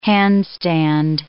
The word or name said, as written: handstand